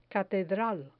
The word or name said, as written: catedral